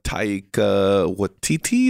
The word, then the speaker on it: Taika Waititi: It's the new Taika Waititi. Is that how you say his name? – Oh, yeah.